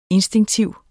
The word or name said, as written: instinktiv